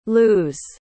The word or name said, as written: luz